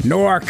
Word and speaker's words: Newark: actually from Newark. That's how they say it up there, Newark.